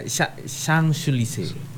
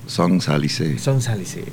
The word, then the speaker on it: Champs-Élysées: Champs-Élysées. – Champs-Élysées. – Tapi tulisannya tuh Champs. – Iya. – Élysées. – Iya. – Oh iya. Susah ya. Pelafalan Prancis tuh ya, ya Pak? – Iya, paling sulit itu.